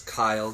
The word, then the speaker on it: Keil: That's Kyle, K-E-I-L. He's Hitler Youth German, so they pronounce the last vowel before the first vowel, so they go Kyle.